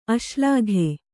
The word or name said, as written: ಅಶ್ಲಾಘೆ